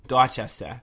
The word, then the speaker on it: Dorchester: Dorchester.